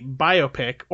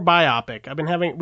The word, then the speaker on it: biopic: Is it biopic or biopic? – Biopic. Biopic, that's ridiculous. Whoever said that is wrong.